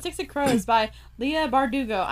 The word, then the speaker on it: Bardugo: Ever since I figure out how to say it, I'm like Bardugo.